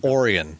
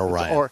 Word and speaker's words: Orion: That's where the and it's Orion, not Orion. – Orion plant. – We pronounce things weird here in Michigan.